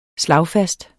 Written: slagfast

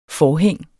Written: forhæng

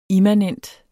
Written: immanent

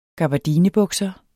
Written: gabardinebukser

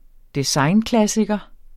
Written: designklassiker

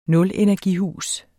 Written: nulenergihus

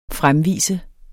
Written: FREMVISTE

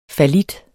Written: fallit

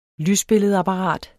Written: lysbilledapparat

